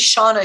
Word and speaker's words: Shawna: And my name is Shawna Schuh, and it's spelled S-H-A-W-N-A and S-C-H-U-H.